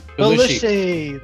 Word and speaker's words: Belushi: You know, imagine if it was Eddie Murphy and John Candy and is it John Buscelli? Did I say that right? Bruce? Was it Bruce? – Belushi. – Belushi, that was him, sorry.